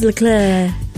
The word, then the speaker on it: Leclerc: Charles Leclerc.